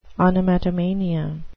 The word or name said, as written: onomatomania